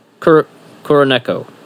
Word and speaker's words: Kuroneko: Kuroneko? Kuroneko. There you go. – We had a big conversation about how you shouldn't say the long A sound.